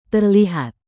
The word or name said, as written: terlihat